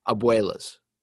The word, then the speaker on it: abuelas: Yes. That's exactly how you pronounce it too. I'm looking at the little spell thing that like has its AH-BHEW-LAHS. That is abuelas.